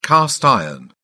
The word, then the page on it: cast iron: British English